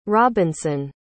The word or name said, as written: robinson